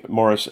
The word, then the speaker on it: Maurice: The Amazing Morris and His Educated Rodents. – Yeah, spelled Maurice, but we're using the English pronunciation.